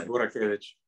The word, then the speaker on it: Burakevic: Is that the right pronunciation? – Yeah, Burakevic.